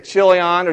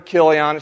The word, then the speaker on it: Chilion: And you may say Chilion or Chilion. It's pronounced both ways.